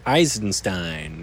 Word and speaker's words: Eisenstein: Eisenstein.